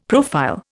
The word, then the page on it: profile: Indian